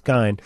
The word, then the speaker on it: Gein: Everybody's pronounced it Gein for years, but as Orion has pointed out, it's not. It's Gein.